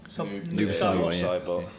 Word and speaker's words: Noob Saibot: Noob Saibot.